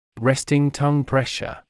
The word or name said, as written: resting tongue pressure